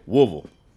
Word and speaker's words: Woodville: And that includes taking on new challenges, like trying his hand at high-speed game hunting in Woodville, Mississippi. – Or like the locals say, Woolville. Ooh, Woolville? – I'll let Isaac take it from here.